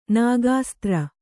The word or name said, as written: ನಾಗಾಸ್ತ್ರ